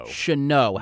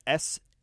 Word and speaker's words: Chanot: Chanot. – How do you spell that? – Maxime Chanot. It's Chanot.